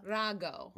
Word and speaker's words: Rago: She's not an heiress to the Ragu family. She made me aware of that. How do you say it again? Rago. – My name is Rosella. – Rosella. – Rago.